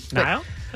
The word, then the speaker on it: Niall: Niall? – That's yeah, right.